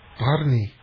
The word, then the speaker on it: Barni: Is the product, I'm not sure how to pronounce it, it's spelled B-A-R-N-I. – Barney.